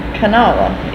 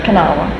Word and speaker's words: Kanawha: here we have Kanawha and Kanawha. – Mm-hmm. Or two pronunciations. – Mm-hmm. – Mm-hmm. – But Kanawha is the correct pronunciation, the Indian pronunciation. – Mm-hmm. – But it's Kanawha or Kanawha.